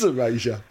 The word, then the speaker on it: Erasure: Is it Erasure? – No. – Oh. – It's Erasure. – Oh, okay, well, I hope you all got a good laugh out of that.